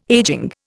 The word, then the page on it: aging: Indian